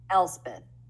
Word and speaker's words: Elspeth: Elspeth.